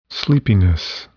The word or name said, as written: sleepiness